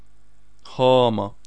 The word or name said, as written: Hama